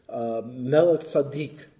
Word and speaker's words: Melchizedek: Melech tzaddik, Melchizedek, was called the king of righteousness. It's a bad English pronunciation of that is Hebrew title.